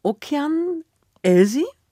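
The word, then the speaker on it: Okean Elzy: Vorher hören wir aber noch Musik von Okean. Elsie.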